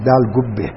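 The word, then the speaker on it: Delagoa Bay: Dalgoa Bay those days, which our Gujarati people should pronounce as Dalgubbe.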